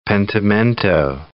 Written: pentimento

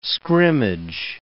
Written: scrimmage

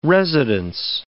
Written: residence

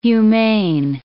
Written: humane

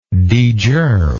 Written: degerm

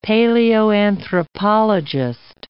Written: paleoanthropologist

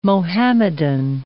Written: Mohammedan

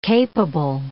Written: capable

